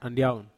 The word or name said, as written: andiaun